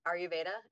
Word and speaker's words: Ayurveda: So it's really interesting that you mention Ayurveda. Is that how you pronounce it? – Yes.